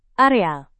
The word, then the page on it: areal: indonesian